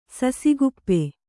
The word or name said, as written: ಸಸಿಕುಪ್ಪಿ